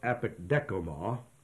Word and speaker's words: apekdechomai: It's pronounced apekdekoma, and it's spelled A-P-E-K-D-E-C-H-O-M-A-I. Apekdekoma.